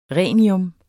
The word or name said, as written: rhenium